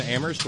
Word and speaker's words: Amherst: Thank you to Smitty and Terry and Amherst, where only the H is silent.